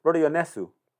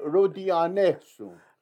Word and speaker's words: Rodionesu: And in fact, you know, you always correct me because I always got the second to last syllable. When we say Rodionesu, right? – Yeah. – I'm getting that one right. – Rodionesu.